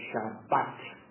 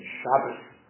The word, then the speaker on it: Shabbat: So for example, in Hebrew, we call the seventh day Shabbat. And in the Yiddish pronunciation, Shabbos. But say the same word. One is the Yiddish and one is modern Hebrew.